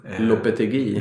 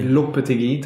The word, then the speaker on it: Lopetegui: Jag kan aldrig uttala hans namn. Nya tränaren L. – Lopetegui. – Lopetegui, tack så mycket.